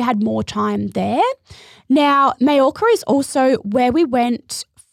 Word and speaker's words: Mallorca: Now, Mallorca is actually spelt with two L's. So if you see that written anywhere, that's how it's pronounced, Mallorca.